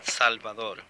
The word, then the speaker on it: Salvador: Juan Salvador. – Could you spell that, please? – Yes. S-A-L.